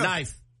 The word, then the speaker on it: knife: Why do we need a K in front of a knife? – Why can't it just be N-I-F-E? Yeah. Knife. – Knife. K-N-I-F-E-H-T. – That's too easy. – Whoever created the silent K, the silent killer.